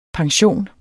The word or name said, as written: pension